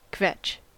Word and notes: kvetch: American English

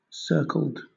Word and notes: circled: British English

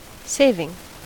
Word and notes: saving: American English

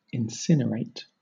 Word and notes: incinerate: British English

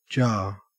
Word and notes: jar: Australian English

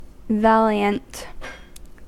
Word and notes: valiant: American English

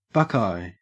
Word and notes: buckeye: Australian English

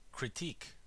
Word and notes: critique: American English

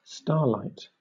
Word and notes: starlight: British English

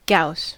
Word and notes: gauss: American English

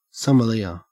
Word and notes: sommelier: Australian English

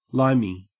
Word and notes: limey: Australian English

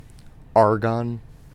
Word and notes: argon: American English

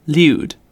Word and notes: lewd: British English

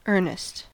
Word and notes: earnest: American English

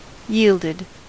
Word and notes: yielded: American English

